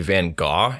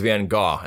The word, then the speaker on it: Van Gogh: Well, so the crazy thing about Van Gogh is, first off, his name is actually Van Gogh. It's pronounced differently.